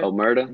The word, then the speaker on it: Omerta: How do you say that? Amarta? – Right? Omerta? – Mm-hmm. Yeah.